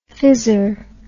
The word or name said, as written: phizzer